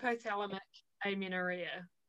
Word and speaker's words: hypothalamic amenorrhea: Hypothalamic amenorrhea.